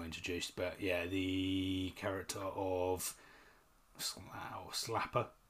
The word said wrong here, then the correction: Slattery: Slattery, not Slappery. Slattery, that's it.